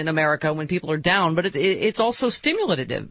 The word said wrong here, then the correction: stimulative: Stimulative, as you say. I put way too many syllables in that word.